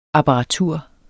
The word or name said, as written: apparatur